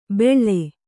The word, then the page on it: ಬೆಳ್ಳೆ: beḷḷe; Kannada